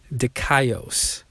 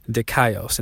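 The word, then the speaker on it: Dikaios (δίκαιος): Dikaios. Dikaios. And that's spelled D-I-K-A-I-O-S.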